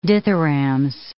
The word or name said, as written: dithyrambs